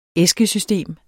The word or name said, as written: æskesystem